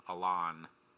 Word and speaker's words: Alton: In Alton, the T is silent. It's Alon. Alton.